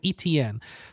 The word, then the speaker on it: Etienne: Etienne, the second best running back in the Etienne.